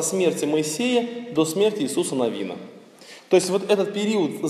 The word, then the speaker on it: Навин: Правильно говорить книгу Иисуса Навина. Ударение на второй слог «Навин».